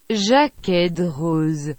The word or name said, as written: Jaquet Droz